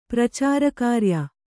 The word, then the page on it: ಪ್ರಚಾರಕಾರ್ಯ: pracāra kārya